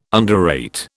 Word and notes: underrate: British English

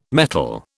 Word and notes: mettle: British English

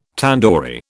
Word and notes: tandoori: British English

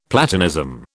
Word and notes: Platonism: British English

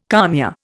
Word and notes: kalmia: Indian

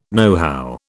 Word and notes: nohow: British English

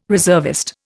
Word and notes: reservist: Indian

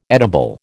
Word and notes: edible: American English

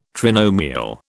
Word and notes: trinomial: British English

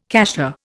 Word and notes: cassia: Indian

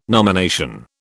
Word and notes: nomination: British English

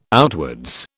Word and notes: outwards: British English

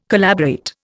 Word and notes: collaborate: Indian

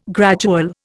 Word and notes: gradual: Indian

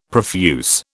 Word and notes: profuse: British English

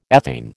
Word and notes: ethane: American English

